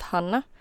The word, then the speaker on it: Hanna: Wait, is it Hannah or Hannah? – Well, actually, it's pronounced Hannah. So, I mean, either is fine because they're both wrong.